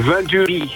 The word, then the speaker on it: Venturi: Zeg ik dat goed of is het gewoon Venturi? Wat vind jij? – Nou, Venturi. Het is Frans. – Oh ja, Venturi.